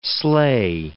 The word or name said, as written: slay